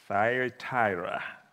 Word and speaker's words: Thyatira: Thyatira.